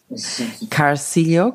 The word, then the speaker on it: Carcillo: Is that the right pronunciation? Carcillo. – Almost, yeah. Carcillo. Yeah. – Carcillo. Okay. I think Carcillo is Argentinian. – Yeah, that's the European way. Carcillo is the Italian way.